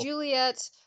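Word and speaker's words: Juliett: No, it's Juliet, but spelled like Juliet, but with two T's and... Yeah, two T's. J-U-L-I-E-T-T.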